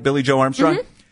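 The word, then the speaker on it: Billie Joe Armstrong: And so as Green Day's set was almost over, Billy Joe Armstrong, Did I get his name right? Billy Joe Armstrong? Mm-hmm.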